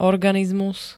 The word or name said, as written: organizmus